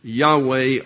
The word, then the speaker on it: Yahweh: And Yehoah, or Jehovah, which was originally spelled without vowels, was written YHWH, pronounced Yahweh or Yahweh.